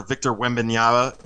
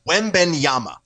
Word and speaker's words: Wembanyama: Next year, Victor Wembanyama. I always have to look at it to pronounce it right. – Wembanyama.